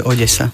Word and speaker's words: Odesa: V slovenčine väčšinou D a E sa vyslovuje meko, de. A keď sa to má vysloviť tvrdo, tak je naznačená tvrdá výslovnosť. Takže ak tam nie je nič hrana tej zátvorke, znamená to, že sa vyslovuje Odesa.